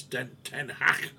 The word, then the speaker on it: Ten Hag: And United are playing Brighton, so I'm hoping that Mr. Ten Hag, as I've got to call him, because my specialist for my back is a Dutchman, and he tells me it's Ten Hag.